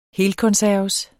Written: helkonserves

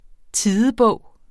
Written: tidebog

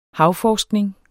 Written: havforskning

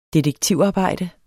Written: DETEKTIVARBEJDE

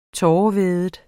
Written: tårevædet

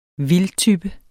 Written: vildtype

